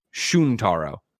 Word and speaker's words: Shuntaro: Greg pronounced it as Shuntaro. It's Shuntaro. I fucking destroyed it. I'm so sorry. Yeah, like Shuhei. Tayrell713 says, BuzzFeed multiplayer's Kelsey Impichi is pronounced M-P-C-H-A-K. M-P-C-H-A-K. M-P-C-H-A-K, maybe.